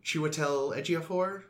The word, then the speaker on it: Chiwetel Ejiofor: Chiwetel Ejiofor? – Yeah.